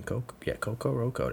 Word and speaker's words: Kokoroko: I mean, Coco Roco. That's how it's pronounced. K-O-K-O-R-O-K-O.